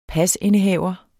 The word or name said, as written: pasindehaver